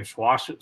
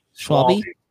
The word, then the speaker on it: Schwalbe: Schwalbe. – Schwalbe.